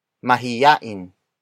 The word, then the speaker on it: mahiyain: Word for word it's mas mahiyain siya kaysa sa akin.